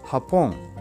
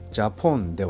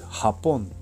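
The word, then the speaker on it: Japón: ジョビボエンハポン。私は日本に住んでいますという意味です。このハポンは J を使って書くんですが、ジャポンではなくハポンと発音をします。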